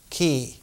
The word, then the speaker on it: Qi: The fifth phase for the Taoists was always Qi, or in the old pronunciation, Qi, C-H apostrophe I.